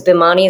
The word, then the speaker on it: Bhimani: I can't decide whether we should call it the Bimaniacs because Bhimani is how you pronounce it, mm-hmm.